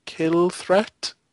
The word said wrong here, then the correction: Kilrathi: What are they called? – Kilrathi. – Kilrathi, there we go.